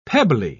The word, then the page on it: pebbly: British English